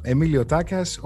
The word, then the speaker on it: Emilio Takas: Emilio Takas, all the way from Athens, Greece.